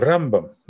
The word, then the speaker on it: Рамбам: Поэтому Раби Моше бен Маймон называется всегда Рамбам на первый слог ударения, а Раби Моше бен Нахман называется Рамбан на второй слог ударения. Это вот подсказка.